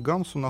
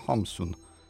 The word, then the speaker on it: Хамсун: Но, э, и, собственно, вот э, почему он стал Гамсун, вот, строго говоря, по-норвежски он, конечно, не Гамсун, а Хамсун. Вот, то есть Гамсун такое очень уже русифицированное э, произношение.